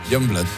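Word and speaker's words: Yungblud: ¿Cómo se pronuncia? – Youngblood.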